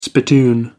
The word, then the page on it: spittoon: American English